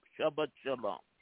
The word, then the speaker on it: Shabbat Shalom: Jewish, so I'm pretty much, I said it correctly, Shalom, Shabbat Shalom. – Yes, so it's okay. – Yeah, you said it correctly.